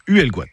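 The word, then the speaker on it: Huelgoat: Nous sommes dispersés partout dans ce bois haut, d'où le nom breton Huelguat, le haut bois, qui se prononce Huelguat.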